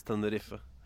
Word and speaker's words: Tenerife: Bare gjenta det, for dere som synes det irriterende med Tenerife. Den en uttales dessverre. – Ja, den gjør det. Det sånn de gjør det. Det sånn vi gjør det, for så vidt. Uttaler de ene som der. – Ja, men vi glemmer det. Folk flest sier jo fortsatt Tenerife.